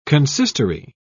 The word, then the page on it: consistory: British English